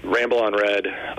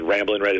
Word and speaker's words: Ramble On Red: Ramble on Red, which is still a year-round beer, which are ESB, and which always gets mispronounced as Ramblin' Red. It's like it's Led Zeppelin. It's not the Allman Brothers.